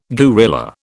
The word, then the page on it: guerrilla: British English